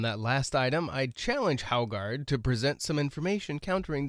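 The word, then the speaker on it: Haugaard: as Republican Speaker of the House Steve Haugard... – That's how you pronounce it. Very good. – Haugard, really? – Haugard.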